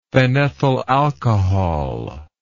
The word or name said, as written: phenethyl alcohol